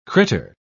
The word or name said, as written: critter